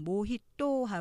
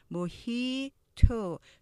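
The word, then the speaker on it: mojito: 다음 보시면 우리가 보통 모히또 하고 쌍 디귿으로 발음하기 쉬운데 음절은 3절 맞아요. 모히 토 하지만 중간에 액센트 있죠. 모히 to 뒤에는 살짝 t 를 발음하시고요.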